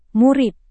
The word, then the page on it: murid: Indonesian